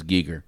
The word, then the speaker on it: Giger: And they must say his name a zillion times, so it is Giger. – It's Giger. – Okay. I was never sure Giger Geiger.